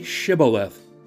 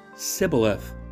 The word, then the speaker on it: Shibboleth: If he answered no, they would tell him, "Say Shibboleth." If he was from Ephraim, he would say Sibboleth, because he couldn't pronounce it right, and they'd grab him and kill him there at the Jordan Fords.